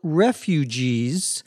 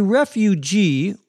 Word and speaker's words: refugee: A refugee, or refugee, either pronunciation is correct, whether the accent is on the first syllable or the last, is a person who has to leave his country, usually because of some violence or threat of violence.